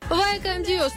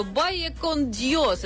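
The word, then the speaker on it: Vaya con Dios: Вайкан Диос, но это приблизительно в нашем произношении звучит так, а вообще, если придираться, то Байекон Диос,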